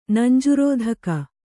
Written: ನಂಜುರೋಧಕ

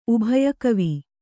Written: ಉಭಯಕವಿ

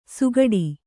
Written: ಸುಗಡಿ

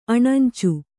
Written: ಅಣಂಚು

